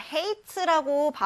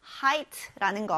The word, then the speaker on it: height: 여기 h-e-i니까 hate라고 발음하고 싶은 친구들 있을 것 같은데 hate가 아니라 height라는 거 유의하세요.